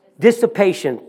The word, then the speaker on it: dissipation: And says, but which is, this is a word that I wanted to make sure that I got right, is a dispassion, did I say that correctly? Dissipation, thank you.